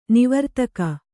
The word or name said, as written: ನಿವರ್ತಕ